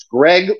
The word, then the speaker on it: Greg: So my last name is, it's Greg with one G at the end, G-R-E-G.